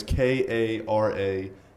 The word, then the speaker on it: Karalla: My last name is Karalla. You pronounce it almost like Cruella de Vil.